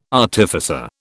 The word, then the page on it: artificer: British English